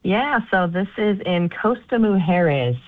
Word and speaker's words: Costa Mujeres: Costa Mujeres.